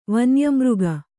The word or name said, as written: ವನ್ಯಮೃಗ